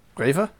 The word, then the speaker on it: REWE: okay, the main supermarket in Germany that isn't Lidl or Aldi is spelt R E W E. How would you pronounce that? R E W E. Uh, uh, what did you say? Raver? Raver? Yep.